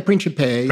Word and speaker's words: Principe: I think, is it Father Principe? Is that how you say it? Yeah, Principe. – Principe. Yes, yeah.